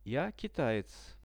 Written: ja kitaec

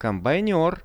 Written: kombainer

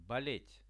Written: bolet

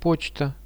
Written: pochta

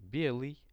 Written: belyj

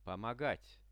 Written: pomogat

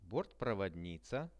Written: bortprovodnica

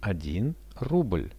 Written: 1 rubl

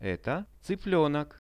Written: eto cyplenok

